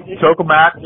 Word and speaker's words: Tokamak: I think they both use, uh, is it Tokamak? Is that the pronunciation? – Yeah, that's right. Tokamak.